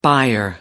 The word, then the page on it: buyer: American English